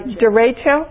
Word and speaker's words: derecho: Derecho.